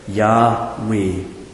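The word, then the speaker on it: Yahweh (יהוה): I'm not going to go into that in detail, but Yahweh is, we're pretty sure, the pronunciation.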